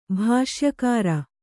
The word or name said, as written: ಭಾಷ್ಯಕಾರ